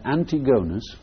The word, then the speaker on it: Antigonus: where Antigonus, which is really how we ought to pronounce his name, he's the Antigonads.